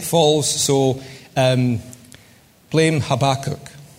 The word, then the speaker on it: Habakkuk: Everyone from the East End of Glasgow knows it's Habakkuk.